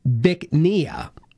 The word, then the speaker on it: Bich Nga: It's called, well, it's pronounced Bicnia, but it's spelled B-I-C-H-N-G-A.